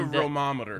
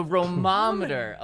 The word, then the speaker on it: Romometer: I believe it's the Romometer. – The Romometer. Okay, yes. Pardon me for saying that wrong.